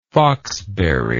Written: foxberry

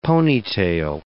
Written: ponytail

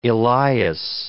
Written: Elias

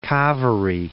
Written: Kaveri Falls

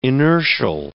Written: inertial

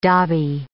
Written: dobby

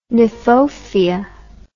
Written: kniphofia

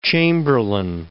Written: Chamberlin

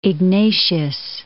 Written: Ignatius

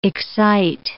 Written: excite

